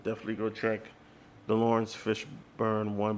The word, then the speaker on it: Fishburne: Was it always Fishburne or Fishborn? I think it's Fishburne. Yeah, it's always been Fishburne.